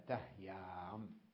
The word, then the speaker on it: تهيام: ومثلها تهيام، تهيام تحركت الياء وهي حرف علة والهاء قبلها حرف صحيح وهو ساكن، والأصل أن تنقل الحركة إلى الهاء وتقلب هذه الياء ألفا، لكن إن قلبناها يصبح عندنا ألفان ساكنان لا يمكن النطق بهما على الإطلاق، فاستثنيت هذه الكلمة من القاعدة وبقيت مصححة هذه الياء، فقيل تهيام يمكن النطق بها.